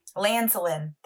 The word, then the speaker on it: lanolin: Lanolin. That's what it is. Lanolin. Why are you laughing? That's what it is. – I do not trust your pronunciation of things. – Rude. Lanolin. There's no S.